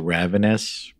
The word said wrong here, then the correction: Ravines: Well, there's Ravines.